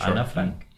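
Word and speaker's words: Ana Frank: If you have nothing to do with that kind of story, Anne Frank. That's the Spanish pronunciation. – Yeah, right. – Anna Frank.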